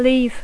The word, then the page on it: leave: English